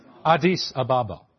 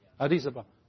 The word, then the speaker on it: Addis Ababa: Addis Ababa. Addis Ababa.